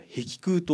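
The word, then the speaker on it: 碧空: それは壁空と読みます。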